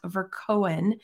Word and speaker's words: Verkoeyen: Dot, Verkoeyen. And I feel still feel like I'm not quite saying that right. I'm so sorry. – Oh, that's uh, perfect.